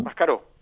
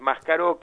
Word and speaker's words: Máscaró: Máscaró. – Máscaró, sí. – Bien con acento en la O, para que nos hacen el chiste sin acento, pero no. Es Máscaró, que es uno de ¿cuál es el chiste? – Y sacarle el acento a Máscaró.